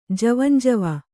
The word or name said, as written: ಜೀವಂಜೀವ